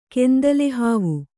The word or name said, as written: ಕೆಂದಲೆಹಾವು